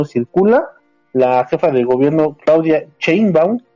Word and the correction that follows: Sheinbaum: ah, no, Sheinbaum, ¿verdad? ¿O cómo se dice? – Sheinbaum, Sheinbaum, por favor.